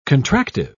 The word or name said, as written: contractive